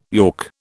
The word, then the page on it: york: British English